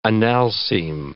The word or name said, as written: analcime